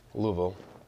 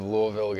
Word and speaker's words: Louisville: He's like, no, you're saying it wrong. You gotta say it like you got stuff in your mouth. – Louisville. – Yeah. I don't know. – The less you move your lips, the more like Louisville.